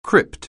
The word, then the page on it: crypt: anglais